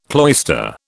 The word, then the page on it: cloister: British English